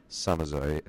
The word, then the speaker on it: Samazeuilh: Just for the record, Samazoy is spelled S-A-M-A-Z-E-U-I-L-H. So quite unusual spelling, but once you know the basic rules of French, there you go. And I checked the pronunciation on that composer's name with Stéphane Lemelin, who actually gave me that disc. So I think I'm getting it fairly close to right.